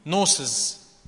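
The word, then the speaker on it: نوسز (γνῶσις): كلمة غنوصية هي أصلا جاية من الكلمة اليوناني نوسز. حد شايف الجي؟ الجي سايلنت، ما بتتنطقش. نوسز يعني نوسز يعني معرفة، أنك تعرف.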